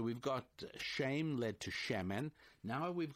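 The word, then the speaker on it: Shemen (שמן): Now, if we add one letter to the end of that, the letter N for November, we get Shemen. And it's a three-letter word. Spelled S, if I were to transliterate it, I'd spell it S-H-E-M-E-N.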